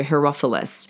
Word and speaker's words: Herophilus: Now, his name is Herophilus. I think I'm saying that wrong. It looks right. It's the word hero and then Phyllis, so Herophilus.